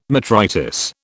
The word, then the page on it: metritis: British English